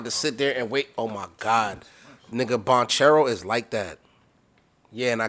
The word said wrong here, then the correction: Banchero: It's Banchero, but it's okay.